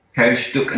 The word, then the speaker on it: Teilstück: Well, it has the word tile, I think tile, and then Stück. Stuck. I think, right? – Teilstück.